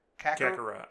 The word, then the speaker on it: Kakarot: Kakarot? Am I saying that right? – Kakarot. – Well, no, technically he is saying it right. – Kakarot.